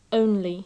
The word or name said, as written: only